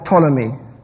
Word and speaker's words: Ptolemy: Let's look at the canon of Ptolemy. It's a silent P, by the way. Like in tsunami, it's a silent T, right?